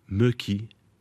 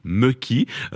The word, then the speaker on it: mökki: Et le troisième, mökki.